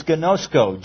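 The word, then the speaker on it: gnosko (γινώσκω): P-R-O. We use that even in some of our English words. And then the word for know, which is gnosko. G-I-N-O-S-K-O. G-I-N, long O, S-K, long O.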